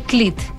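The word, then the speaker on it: Klete: no sé cómo se pronuncia el nombre porque se escribe Clete, pero debe ser Clit.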